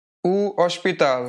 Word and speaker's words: hospital: O hospital. – O hospital. So notice here that hospital actually is written with the H, but you don't read the H. The H is silent.